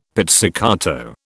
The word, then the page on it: pizzicato: British English